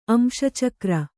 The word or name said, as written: ಅಂಶಚಕ್ರ